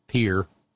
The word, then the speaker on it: Pierre: You know, sitting out in Pierre, South Dakota, Pierre, learned how to pronounce it one time.